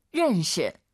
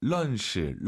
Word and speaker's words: 认识: 认识. – 자, 알 발음 어려우시죠? 런시, 런시. 물론 알아듣잖아요, 선생님. – 是다. – 알 발음 약간 으르, 으르 혀를 뒤로 보내면서 런시.